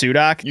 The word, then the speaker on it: Sudock: It's SUDOCK? – You did.